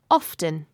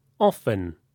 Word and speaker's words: often: Yes, some people say often like this. Often. You can hear the t sound. Often. Other people pronounce it with a silent t. – Like this. Often. Often. Both ways are acceptable.